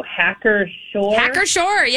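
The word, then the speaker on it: Hacker-Pschorr: Hackershore, yeah, you did good.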